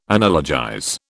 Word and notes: analogize: British English

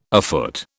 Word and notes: afoot: British English

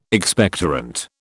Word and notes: Expectorant: British English

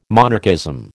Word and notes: monarchism: American English

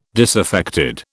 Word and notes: disaffected: British English

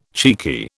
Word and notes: cheeky: British English

